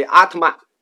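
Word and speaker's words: ātman: 佛教里的“ 我” 实际是个外来词，音译为阿特曼，就跟日本动画里的奥特曼那发音差不多，阿特曼。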